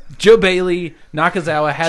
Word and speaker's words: Nakazawa: Michael Nakazawa is going to do the whatever his fucking name is. – It's you Michael said it right. Nakazawa.